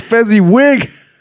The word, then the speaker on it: Fezziwig: Listen here, you old Fezwick. – He even said that wrong. – What? – It's Fezziwig.